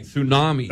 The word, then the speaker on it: Tsunami: With the T? – Yeah, with the silent. Wow. Yeah. With the silent T and everything, Tsunami.